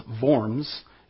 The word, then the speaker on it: Worms: Okay, the diet was the formal assembly, and Worms, actually pronounced Worms, is a city in Germany.